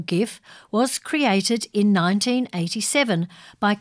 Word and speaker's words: GIF: who insisted at the 2013 Webby Awards show that the format was always intended to be pronounced as GIF, with a soft G, as a nod to the American peanut butter brand, GIF.